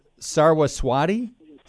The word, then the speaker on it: Saraswati: There's the one I'm looking at now is, how do you pronounce it? Saraswati? – Yes.